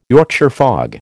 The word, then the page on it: Yorkshire fog: American English